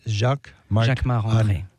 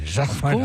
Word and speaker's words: Jacquemart-André: Jacquemart-André. Arnaud, you say it like a Frenchman. Jacquemart-André. – Of course, because I'm French. So Jacquemart-André.